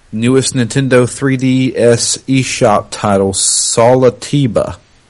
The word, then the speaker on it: Solitiba: S O L I T I B A.